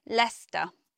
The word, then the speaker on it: Leicester: But actually, it's only two syllables, and we simply say Leicester.